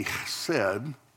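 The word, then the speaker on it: chesed (חסד): Again, because we've talked about this a lot in the past, and it didn't come through clear, but there's your H, the short E, the S sound, reading from right to left, the short E, and then the D, chesed.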